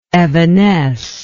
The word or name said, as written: evanescible